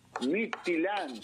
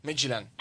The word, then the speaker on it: Midtjylland: Porque el club se llama Midtjylland.